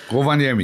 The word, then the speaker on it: Rovaniemi: Viele Grüße aus Rovaniemi. Ich hoffe, ich habe es richtig, ja, ausgesprochen. – Rovaniemi, ja.